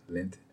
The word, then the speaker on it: Lindt: It's Lindt, L-I-N-D-T.